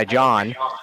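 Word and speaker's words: Jon: Because I go by John, and it's J-O-N, not J-O-H-N, not J-H-O-N.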